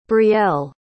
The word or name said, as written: brielle